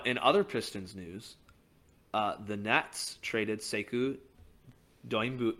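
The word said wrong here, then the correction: Doumbouya: I can't even pronounce his name. – Dumboya, to the Rockets for a second round pick, and then the Rockets proceeded to cut Sekou.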